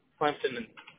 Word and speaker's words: Clemson: Clinton? Like the president? – No, Clemson. Like the Clemson football team.